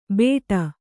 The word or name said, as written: ಬೇಡ